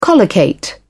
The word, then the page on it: collocate: British English